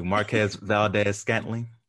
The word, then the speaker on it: Marquez Valdes-Scantling: Marquez Valdes-Scantling.